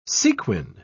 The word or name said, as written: sequin